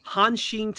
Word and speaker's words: Hanshin: so so for you Justin now plays for the Hanshin Tigers.